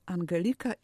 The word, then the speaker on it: Angelika: Tak, Angelika i już, tak jak Angela i już, i tak jak Angelina i już. Odczytujemy imię zgodnie z zapisem i polskim systemem fonetycznym.